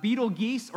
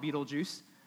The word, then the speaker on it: Betelgeuse: This star is called Betelgeuse, or can also be pronounced Betelgeuse. So you better believe, because we're at Core Church, we're going to call it Betelgeuse.